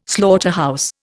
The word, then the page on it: slaughterhouse: Indian